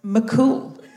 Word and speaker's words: Mac Cumhaill: It's not spelt McCool. It's spelt M-A-C-C-U-M-H-A-I-L-L. But that is spelt McCool, actually. – But M-H is like a W. – In Irish, yeah.